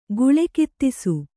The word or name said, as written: ಗುಳೆಕಿತ್ತಿಸು